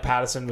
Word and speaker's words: Pattinson: I thought, like, Pattinson was whatever.